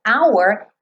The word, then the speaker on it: hour: Então aqui, hour é uma palavra no inglês que o H é mudo.